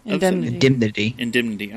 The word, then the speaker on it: indemnity: The Revenue Act of 1767, the Indemnity Act... – Indemnity. – Oh, I'm adding extra syllables.